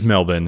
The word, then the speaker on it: Melbourne: It is Melbourne, if you want to say it correctly.